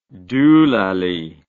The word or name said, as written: doolally